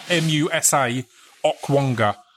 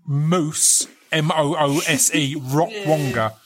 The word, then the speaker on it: Musa Okwonga: And you have to, like, I need to pause quickly and say his name is Moussa, Musa Okwonga, Okwonga, because when I've mentioned him before, I've had people go, I can't find Moose, Moose, Rockwonga.